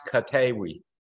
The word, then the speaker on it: Koteewi: It's pronounced Katewi. – Katewi. Okay. – Yeah, the two E's make an A sound. So, and it's Kotee. W-I.